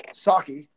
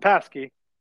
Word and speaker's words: Psaki: Mark, he was on with our former favorite press secretary, Jen Psaki, Yeah, not Paskey. – No, definitely not Paskey.